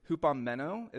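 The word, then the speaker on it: hupomeno (ὑπομένω): So that word steadfast there in Greek, it's hupomeno is how you pronounce it.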